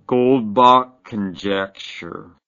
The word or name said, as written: goldbach conjecture